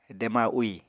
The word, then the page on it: dema úì: apatani